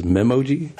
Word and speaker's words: Memoji: I think at this year's WWDC, I heard a lot of the presenters in the keynote pronounce it as Memoji.